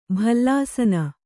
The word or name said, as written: ಭಲ್ಲಾಸನ